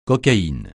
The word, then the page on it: cocaïne: francais